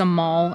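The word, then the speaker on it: Amahl: The title is A Mall and the Night Visitors.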